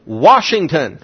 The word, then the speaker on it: Washington: But if you go into some places, the word C-A-N apostrophe T is pronounced can't. There is no I in that word. W-A-S-H-I-N-G-T-O-N is what? Washington, right? Not to some people, Washington. There's no R in that word.